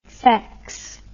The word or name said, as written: fex